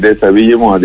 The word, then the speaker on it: Déshabillez-moi: Yo no había oído nunca esta canción, doctor Enrique, Juliette Greco, Déshabillez-moi, ¿se dice? ¿Lo dije bien? – Sí, es Déshabillez-moi, desnúdame.